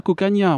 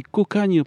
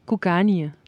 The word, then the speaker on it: Cocanha: Alors on dit tout de suite, alors euh, aux auditeurs qui vous connaissent pas, euh, ça s'écrit C-O-C-A-N-H-A. Donc on dit pas Cocagna, on dit Cocagne parce que c'est de l'occitan.